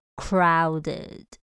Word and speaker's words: crowded: 形容人很多，这个字你应该会叫做拥挤的，你可以说 it's crowded, crowded。那要小心它的发音哦， cr cr ow 念 ow，尾巴是 ed，所以我们念 crowded, it's crowded。